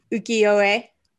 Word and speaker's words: Ukiyo-e: Ukiyo-e.